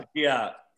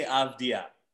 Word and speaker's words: Avdija: How do you say Denny's last name? Don't let me mess up. Avija. – Avija? – Thanks, so. – Avdija. – Okay. What? – It's Denny Avdija.